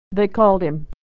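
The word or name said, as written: they called him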